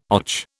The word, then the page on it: och: British English